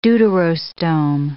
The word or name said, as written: deuterostome